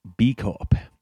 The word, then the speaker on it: Bicorp: And the first place we go is a place called B Corp. That's how we've been pronouncing it. B-I-C-O-R-P.